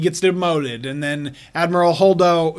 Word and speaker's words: Holdo: Holdo. – Holdo.